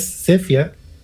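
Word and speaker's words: Sephia: Es Sepia. PH, la PH se pronuncia como una F, y en realidad ese, el contexto del nombre no tiene mucho relato.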